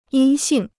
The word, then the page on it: 阴性: yīn xìng